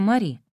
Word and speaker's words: Мэри: Потом кто-то предложил имя Мэри, и отец сказал, что очень хорошо, они могут назвать меня Мэри. А мама сказала, что, конечно, она согласна на Мэри, только пусть произносится Мари.